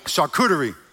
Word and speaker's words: charcuterie: Can never say that word. How you say it? charcuterie. charcuterie.